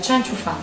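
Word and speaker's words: Chen Qiufan: perdonatemi la pronuncia, Cianciu Fan, giusto? Sì. Grazie.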